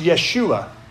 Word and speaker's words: Yeshua: If you say it in Hebrew, it's pronounced Yeshua. That's as close as I can come to pronounce it anyway.